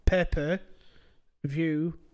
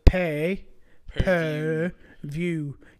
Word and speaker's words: pay-per-view: I'm like, no, it's pay, per view. You pay per viewing.